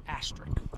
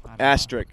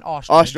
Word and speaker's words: asterisk: Asterisk. How do you say that? – Asterisk. – Asterisk. – Know. – Oh, he said. What's what? – Not an ostrich. – Ostrich is what it sounds like. Ostrich is a bird.